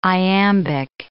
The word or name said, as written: iambic